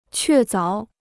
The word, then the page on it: 确凿: què záo